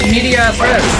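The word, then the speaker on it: in medias res: Does not necessarily mean it's pronounced with an S. – Okay, maybe you don't pronounce the S. Follow me. – We're gonna escape from this city. – Yeah! You pronounce the rest! In media as rest! Well, okay, I think that's the Oxford way. That's the Oxford pronunciation.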